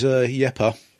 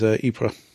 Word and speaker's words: Ypres: Well, the Flemish is Yepa. – Okay. – And the French is Ypres.